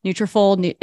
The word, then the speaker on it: Nutrafol: Nutrafol is that how you say it? – Yep.